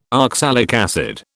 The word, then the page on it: oxalic acid: British English